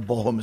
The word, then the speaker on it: Bochum: I know Bochum is right.